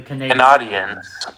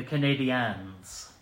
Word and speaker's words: Canadiens: Say it right. The Canadiens. I don't know. – The Canadiens. Because they're French.